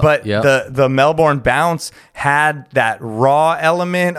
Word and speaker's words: Melbourne: Melbourne. That's how people should pronounce it, Melbourne, but yes. We just say Melbourne.